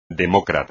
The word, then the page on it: demócrata: espagnol